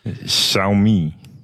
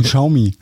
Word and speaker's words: Xiaomi: Du kannst sowas aussprechen. – Xiaomi. – Wo ist denn das? – Ja. Nicht aus Hessen, aber wir sagen Xiaomi.